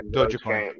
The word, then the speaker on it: Dogecoin: Dogecoin. – Yeah, Dogecoin.